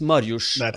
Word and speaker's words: Mariusz: And it's Mariusz, I'm sure.